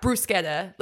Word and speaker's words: bruschetta: I think the trick is to not roll the R, but like pronounce the CH hard. – So do it that. – Bruschetta.